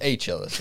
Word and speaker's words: Achilles: You know that's pronounced Achilles, right? – Achilles, yeah.